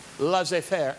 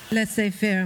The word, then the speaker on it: laissez-faire: And we're helping them by having a laissez-faire attitude. Did I say that right? Laissez-faire attitude. See, I can quote in many languages. – Laissez-faire, monsieur. – Laissez-faire. Okay, I got it.